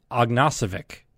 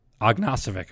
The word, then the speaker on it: Ognacevic: No, excuse me. I got the name wrong again. It's going to take me a little bit to be able to get this right. The pronunciation is Jacob Ognacevic. Ognacevic. Okay, I've been saying it wrong for so long, and so you'll see that.